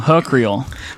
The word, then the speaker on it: Oakhurst: Oakhurst.